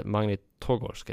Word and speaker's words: Magnitogorsk: Och sen släpptes det till slutspelare, då kunde han lira för Metallurg Magnitorsk. Eller Magnitogorsk heter de. Ja, du ser. – Bra uttal.